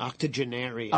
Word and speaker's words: octogenarian: What's how do you say that word again? Octa. – Octogenarian. Octogenarian. – Oh um, unfortunately, octogenaric. – No.